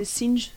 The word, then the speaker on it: Synge: Donc il y a un passage où il cite Synge, S-Y-N-G-E, c'est Synge, on le prononce.